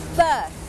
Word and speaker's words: berth: berth berth berth อ่านออกเสียงเหมือนกันกับคำว่า birth birth